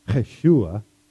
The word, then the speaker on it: Yeshua: And the word Jesus which they would have pronounced it more like Yeshua, comes from the name Joshua in the Old Testament.